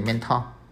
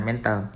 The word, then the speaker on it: mentor: Mentor nha mọi người, không phải là mentor. Việt Nam mình thì rất là hay nhầm lẫn cái chữ này, mọi người hay đọc là mentor, nhưng mà thật ra nó là mentor ha.